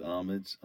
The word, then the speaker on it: homage: I think it's homage. I don't think you pronounce the H.